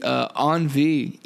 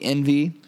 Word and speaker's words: Envie: It is the Envie, E-N-V-I-E, not the Envy, as Hutch wants to say.